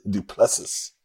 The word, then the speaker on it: Du Plessis: Driscus Duplessis. – Duplessis. – Duplessis. – Yeah, you're talking about two guys that are really good when it comes to the stand-up. And so you look at this, Du Plessis comes from a complete stand-up background.